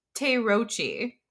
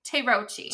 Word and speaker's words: Terauchi: And I asked him how to pronounce this, and it's, I'm going to say it wrong, even with his instructions, but it's Terauchi. – Terauchi.